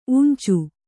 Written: ಊಂಚು

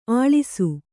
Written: ಅಳಸು

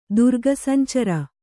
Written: ದುರ್ಗಸಂಚರ

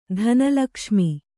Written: ಧನಲಕ್ಷ್ಮಿ

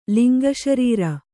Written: ಲಿಂಗಶರೀರ

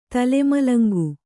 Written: ತಲೆಮಲಂಗು

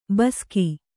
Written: ಬೇಸಿಗೆ

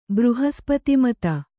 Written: ಬೃಹಸ್ಪತಿಮತ

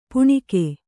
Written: ಪೆಣಕೆ

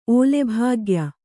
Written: ಓಲೆಭಾಗ್ಯ

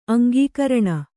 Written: ಅಂಕಕರಣ

